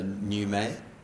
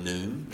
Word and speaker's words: Newmay: Rylan Newmay, now I got the correct pronunciation. It's actually French, so Newmay, nice. Rather than Noom. So sorry, Rylan. – It's not Noom.